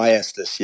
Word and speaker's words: Maestas: Is it Maestas? Uh, um, uh, Maestas. Yes. All right.